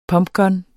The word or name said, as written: pumpgun